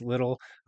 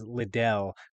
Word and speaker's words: Liddle: So you mentioned my name is Marquese Liddle. Unfortunately, it's not as fancy as Liddell, though that's how everyone pronounces it.